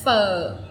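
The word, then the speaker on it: phở: No, pho. It's like a question mark. Question mark on top of the O. – Pho. – Pho. Like you bend your voice and you drop it very quick.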